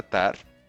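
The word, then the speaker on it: Tár: De hecho, por eso se llama así la peli Tar con, con acento en la A.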